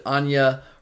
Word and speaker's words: Anya: Anya, spell like we would spell Anna here in the States, but Anya Hertel, we're going to say is how you pronounce that.